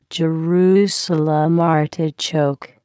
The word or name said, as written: Jerusalem artichoke